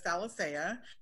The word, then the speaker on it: Alicea: I'm on Facebook um, it's it's just under my first and last name, which is Candace Alicea.